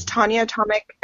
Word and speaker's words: Tonjia Atomic: It's Tonjia Atomic, and it's spelled T-O-N-J-I-A Atomic.